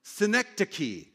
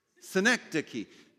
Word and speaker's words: synecdoche: Synecdoche. Say this. Synecdoche.